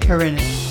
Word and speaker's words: Horinek: With Keith, say your last name. – Horinek. – Keith Horinek, because it's not Hornet, it's Horinek.